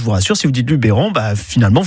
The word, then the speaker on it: Luberon: En fait, en provençal, le E se prononce E. Donc, si vous dites Luberon, bah, c'est presque comme la prononciation d'origine de cette région. Je m'explique. La prononciation d'origine en provençal, c'est Luberoun.